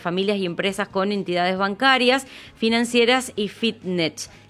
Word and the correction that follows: Fintech: ¿Se dice así? – Fintech. – Sí, Fintech. Ahí está, me trabé.